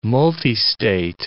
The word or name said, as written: multistate